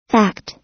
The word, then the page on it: fact: American English